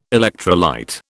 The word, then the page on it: electrolyte: British English